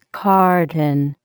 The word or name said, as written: carton